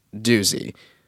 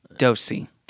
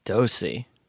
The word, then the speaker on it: Doose: Okay, the guy who names the market is named Taylor Dosey, but the last name is spelled D-O-O-S-E. Is that weird to you, or is that like whatever? – D-O-O-S-E? – Yeah. – And it's pronounced Dosey. – Dosey.